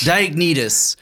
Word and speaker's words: Diognetus: Diognetus.